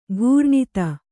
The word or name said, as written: ಘೂರ್ಣಿತ